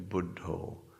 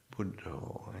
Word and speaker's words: Buddho: and in the Thai pronunciation, a Buddho, Buddho, and you recite the Buddha's name, and part of it is a mindfulness tool.